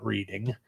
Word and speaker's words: Reading: I said Reading, not Reading. Mm-hmm. It is spelled Reading, folks, but it is pronounced Reading.